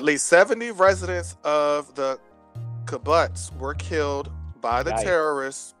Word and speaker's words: kibbutz: It said the journalists were let into the Kfar Azar kibbutz located just outside the kibbutz. It's kibbutz. Oh. – How you spell that? – Kibbutz. K-I-B-B-U-T-Z.